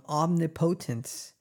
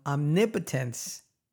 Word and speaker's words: omnipotence: He must himself gain fresh hold on omnipotence. Again, I keep saying that word wrong. he must himself gain a fresh hold on omnipotence. That's how you say it.